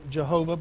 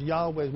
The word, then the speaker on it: Yahweh: and some pronounce it Jehovah, but there's no J in Hebrew, so it's Yahweh most likely is the best way to pronounce it.